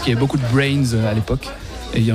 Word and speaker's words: BRNS: Exactement, et donc c'est finalement très prononçable parce que c'est juste Brains, on a enlevé les voyelles.